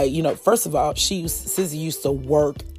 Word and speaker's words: SZA: I think it's SZA. It's S-Z-A. SZA. See, count this as my age.